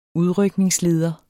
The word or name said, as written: udrykningsleder